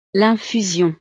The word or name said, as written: l'infusion